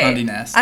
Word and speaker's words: Condé Nast: Yes, so Condé Nast. Is that how you say it? – Yeah, okay. – Condé Nast.